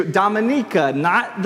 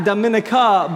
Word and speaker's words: Dominica: and then another team to Dominica, not the Dominican, not Dominica, but Dominica is how you pronounce it, a tiny island nation where 80% of the structures on the island were severely damaged by Hurricane Maria.